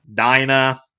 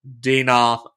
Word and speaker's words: Dinah: where the inhabitants of Canaan raped their sister Dinah, Dina, I've heard both. I do not care. I will know who you're talking about. Say it however you want to say it. I think the technical pronunciation is Dina.